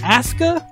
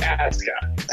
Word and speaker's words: Asuka: Aska? – Is that how we're saying it? WWE's doing one thing right because Japanese pronunciation, you never pronounce the U. – Okay, hmm. but it's Asuka.